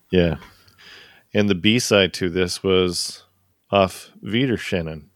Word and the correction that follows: Auf Wiedersehen: Did I say that right? – Auf Wiedersehen. – Is it just saying? – Yeah. Auf Wiedersehen. – You sure? – I'm positive.